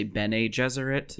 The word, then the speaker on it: Bene Gesserit: So you said Bene Gesserit? – That's how I do it. I think I've also heard people say Bene Gesserit. – Yeah, I said Bene Gesserit, like Anthony Jeselnik.